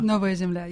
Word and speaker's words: Novaya Zemlya: Am I pronouncing that correct? Zemlya? – Novaya Zemlya, yeah.